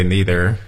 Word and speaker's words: neither: Americans usually say neither. – Right. – And、perhaps British or some very educated Americans will use neither, the more formal pronunciation.